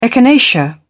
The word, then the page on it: echinacea: British English